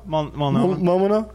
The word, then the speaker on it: Multnomah: Multnomah.